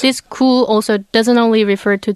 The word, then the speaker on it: 구: And the sound, pronunciation is 구.